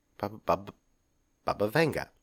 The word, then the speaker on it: Baba Vanga: And then, forgive me again, Baba Vanga is, I believe, how it's pronounced. – We don't talk about Baba Yaga. – Right. Not Baba Yaga. Baba Vanga.